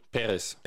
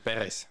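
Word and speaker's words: Pérez: Und dann haben wir noch Emilia Perez. Ich muss es so oft neu aussprechen, weil der Akzent liegt auf dem E, auf dem ersten E. – Perez. – Perez.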